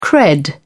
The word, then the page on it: cred: British English